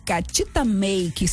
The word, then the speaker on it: CatitaMac: Acesse o Insta, @CatitaMake. Se escreve CatitaMac, mas se lê CatitaMake, tá?